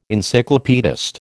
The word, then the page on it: Encyclopaedist: American English